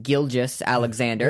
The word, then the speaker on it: Gilgeous-Alexander: Yes, his name's pronounced Gilgeous-Alexander.